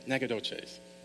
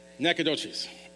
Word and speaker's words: Nacogdoches: Did I say that right, by the way? Nacogdoches. I did not even close. Thanks. Say it then. Say it. Nacogdoches. Say it really fast and mumble, then it's right.